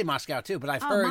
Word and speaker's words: Moscow: I heard they pronounce it Moscow over there. I remember someone telling me there's no cow in Moscow.